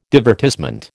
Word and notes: divertissement: American English

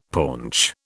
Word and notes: paunch: British English

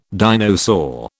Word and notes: dinosaur: British English

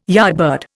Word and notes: yardbird: Indian

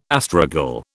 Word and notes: astragal: British English

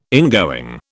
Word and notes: ingoing: British English

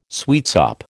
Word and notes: sweetsop: American English

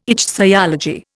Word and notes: ichthyology: Indian

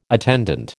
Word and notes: attendant: American English

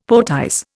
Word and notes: poetize: Indian

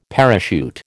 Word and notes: parachute: American English

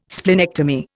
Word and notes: splenectomy: Indian

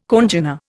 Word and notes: congener: Indian

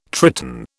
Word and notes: Triton: British English

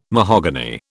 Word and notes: mahogany: British English

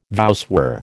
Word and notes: voussoir: American English

